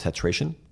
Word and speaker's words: Tetration: And the other is a product called Tetration. – How do you spell that? – T-E-T-R-A. T-I-O-N. So kind of think of titration.